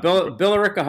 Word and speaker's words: Billerica: I always pronounce it wrong. – Billerica High School, Billerica Memorial High School.